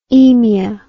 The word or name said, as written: aemia